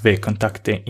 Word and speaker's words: VKontakte: It's actually pronounced VKontakte in Russia, and it's quite popular.